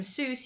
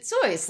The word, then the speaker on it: Seuss: "You're wrong as the deuce, and you shouldn't rejoice if you're calling him Seuss. He pronounces it Zoyce." Like, there's clearly a sing-song here, and you killed it dead. – No, I'm not in it for that. I'm in it for the pronunciation. It rhymes with rejoice, people.